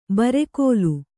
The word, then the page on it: ಬುರುಗಲು: burugalu